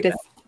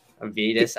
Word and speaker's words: Vitus: Is that, I'm not sure if I butchered Vitus. – Vitus, okay.